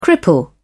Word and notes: cripple: British English

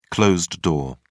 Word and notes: closed-door: British English

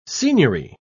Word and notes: signory: British English

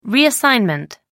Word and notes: reassignment: American English

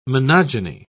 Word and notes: monogyny: British English

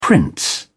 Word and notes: prince: British English

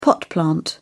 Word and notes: pot plant: British English

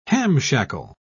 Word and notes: hamshackle: British English

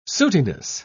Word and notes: sootiness: British English

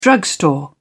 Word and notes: drugstore: British English

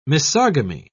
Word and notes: misogamy: British English

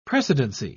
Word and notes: precedency: British English

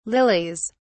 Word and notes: lilies: British English

